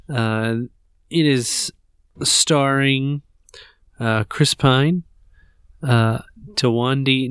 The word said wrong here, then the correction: Thandie: Newton. – Thandie. Thandie Newton. That's how you ah, pronounce it. Thandie.